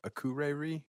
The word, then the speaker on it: Akureyri: And they're from Akureyri. How do you say that? Akureyri, Iceland. – Sure.